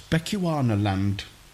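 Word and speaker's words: Bechuanaland: "Miss, how do you pronounce this?" And she looked at it and she said, "That's Bechuana land.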